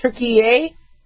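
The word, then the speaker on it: Türkiye: I think they want to be pronounced Turkey-ay, but not pronounced Turkey.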